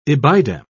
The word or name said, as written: ibidem